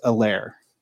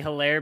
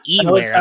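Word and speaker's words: Helaire: I don't know if you were listening to the announcers that CEH, his last name, the H is silent. I have not heard anybody else pronounce it without, you know, they were basically calling him Edwards Alaire.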